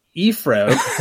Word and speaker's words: Heathrow: So everyone get ready for the feel good episode, which will kick off with your first flight to what you called Eefrow, which I guess is how the Brits say it. For everyone else in the world, it's in English, it's London Heathrow.